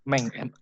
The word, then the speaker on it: Menk: Menk, M-A-N-K ya? – M-A-N-K, Menk. Hmm. Bacanya Meng ya, bukan Komeng ya.